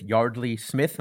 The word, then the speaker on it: Yeardley Smith: And you're going to stick with Ted Cruz and with Yardley Smith. Am I saying that right? – Yes. Yeah.